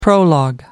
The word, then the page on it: prologue: American English